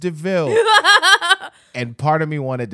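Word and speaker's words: de Vil: So when he pulled up and was like, it's DeVille. It's spelled like devil, but pronounced DeVille.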